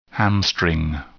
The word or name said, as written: hamstring